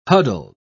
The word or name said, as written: huddled